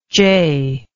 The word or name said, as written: jeh